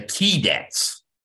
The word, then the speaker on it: Keydets: The Key Dets. – The Key Dets. – So It's spelled K-E-Y-D-E-T-S.